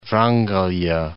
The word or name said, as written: Vrangelya